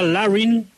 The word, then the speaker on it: Larin: Hizo 2 goles un jugador que se pronuncia Larin, es un número 9, Hernán, 1.88, es del Besiktas, 26 años.